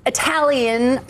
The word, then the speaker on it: Italian: But Italian Prime Minister rhymes with stallion, Giorgia Meloni.